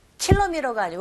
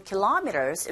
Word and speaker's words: kilometers: 킬로미터가 아니고 kilometers 이렇게 발음하시고요.